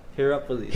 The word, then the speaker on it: Hierapolis: Hierapolis.